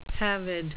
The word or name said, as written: pavid